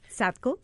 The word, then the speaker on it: SADCO: How do yes. – you pronounce it in English? – SADCO. – SADCO.